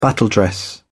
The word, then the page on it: battledress: British English